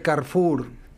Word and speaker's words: Carrefour: Y se pronunciaría Carrefour.